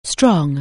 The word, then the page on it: strong: anglais